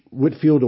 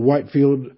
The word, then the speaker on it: Whitefield: George Whitefield or Whitefield, pronounced either way.